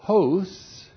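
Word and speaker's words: hos (ὡς): And then we have the comparative particle hos, H long O S, hos.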